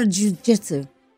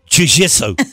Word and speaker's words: jiu-jitsu: How do you say it? No, jiu-jitsu. – Oh, jiu-jitsu.